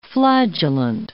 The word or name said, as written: flagellant